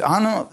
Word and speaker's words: anno: It's Annus Dominus. Is that right? Did I say that right? Annus Dominus, because I saw you, I heard you saying it. AD means anno. It's a Latin word, and you know what it means?